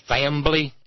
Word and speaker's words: fambly: Anytime you see an organization and its name has in it family anywhere, or as they pronounce it some places, fambly, F-A-M-B-L-Y, fambly, brethren and sister, you know then that you are dealing truly with followers of the risen Christ.